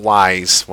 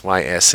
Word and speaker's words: Ys: Yes, it's not Wise, Y-S. It's pronounced Ys.